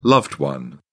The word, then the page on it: loved one: British English